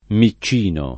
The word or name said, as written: miccino